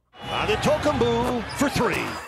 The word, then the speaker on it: Antetokounmpo: And here's how he pronounced Antetokounmpo. – Antetokounmpo for three. – Antetokounmpo for three. He nailed for three. He nailed that.